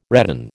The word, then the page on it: redan: American English